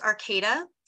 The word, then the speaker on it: Arcata: I almost said Arcadia. Arcata, California. – It's actually pronounced Arcata. – So it is Arcata. Okay. – Yeah, it's Arcata. So it's not Arcadia. That's in Southern California. Mm-hmm. Arcata is in Northern California.